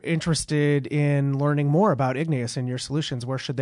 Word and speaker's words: Igneous: We often get confused with Ingenious, so if you spell it that way, you'll end up somewhere else, but it is Igneous like the rock, like the volcanic rock.